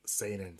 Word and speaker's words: Seinen: Seinen.